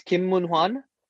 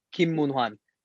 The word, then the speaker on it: Kim Moon-hwan: No, H is not silent. – It's not silent? – So okay. – It's Kim Moon-Hwan. You know, Kim is close enough because that's the way it's spelled, really. But the way we pronounce it, you know, purely from a Korean standpoint, we call him Kim Moon-Hwan.